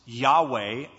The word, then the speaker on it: Yahweh: The tetragrammaton, typically pronounced Yahweh or Jehovah in some older Bibles, comes from four Hebrew letters, hence tetragrammaton, four letters. We would say they're YHVH or WH, and we typically pronounce it Yahweh. Now, we don't know how it was pronounced because we only have those consonants, and we've lost the pronunciation.